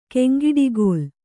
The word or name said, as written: ಕೆಂಗಿಡಿಗೋಲ್